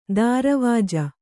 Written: ದಾರವಾಜ

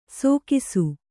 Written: ಸೋಕಿಸು

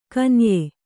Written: ಕನೀಯ

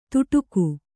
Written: ತುಡುಕು

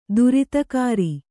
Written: ತೀರ್ಥಕರ

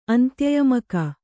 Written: ಅಂತ್ಯಯಮಕ

